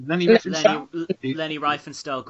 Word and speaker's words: Leni Riefenstahl: the Nazi propaganda filmmaker Leni Riefenstahl, is that how you say it? – Leni Riefenstahl, great director.